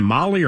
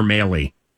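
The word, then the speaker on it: Mahle: Is it Molly or Maley? – I think we have come to, it rhymes with rally, so rally. We understand it's Maley, but yeah.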